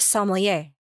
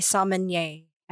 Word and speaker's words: sommelier: And then one thing that I just definitely want to communicate to the listeners is the correct pronunciation of sommelier. – Yes. – There's no N. – No. – And that is a pet peeve of mine when they say sommelier.